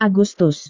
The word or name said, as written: agustus